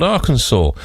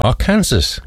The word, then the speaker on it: Arkansas: It's called Arkansas, or as I like to say it, because I know it annoys people, Arkansas, because that's how it's spelled. Let's be fair, there is no W in it, but who are we to argue about how things are pronounced, eh?